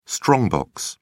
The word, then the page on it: strong box: British English